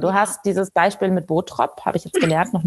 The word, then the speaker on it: Bottrop: Wir betonen eher so ähm, die erste Silbe Botrop. Bot, Botrop.